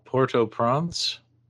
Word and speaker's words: Port-au-Prince: Now you're from Port-au-Prince. – Yes. – Did I pronounce that halfway decently? – Well, yeah, I understand, but it's really it's Port-au-Prince.